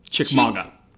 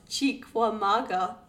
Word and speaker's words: Chickamauga: So Old Green Eyes was linked to the Battle of Chickamauga.